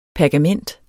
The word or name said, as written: pergament